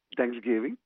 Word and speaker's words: Thanksgiving: Thanksgiving. – Thanksgiving.